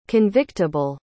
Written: convictable

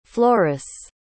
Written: flores

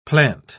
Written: plant

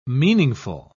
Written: meaningful

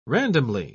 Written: randomly

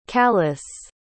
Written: callis

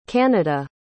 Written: canada

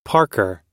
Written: parker

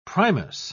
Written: primus

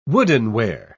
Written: woodenware

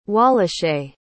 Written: Wallache

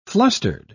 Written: flustered

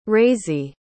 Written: raisie

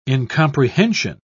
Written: incomprehension

